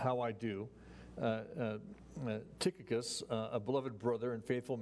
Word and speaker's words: Tychicus: I've been mispronouncing this one name, Tychicus. It's actually Tychicus and Trophimus of Asia there in Ephesus.